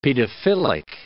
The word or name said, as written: pedophilic